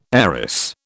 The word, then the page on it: heiress: British English